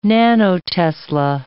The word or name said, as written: nanotesla